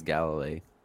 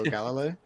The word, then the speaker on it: Galilei: How do you say his last name? – I think it's Galilei.